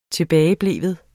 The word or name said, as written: tilbagebleven